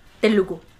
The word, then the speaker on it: Telugu: Este, de hecho, el idioma original de esta película que no está disponible en Netflix es el, te lo digo bien, Telugu.